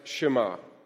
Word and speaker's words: Shema: Because that is the word in Hebrew pronounced Shema.